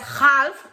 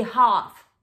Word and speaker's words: half: No digas half, di half.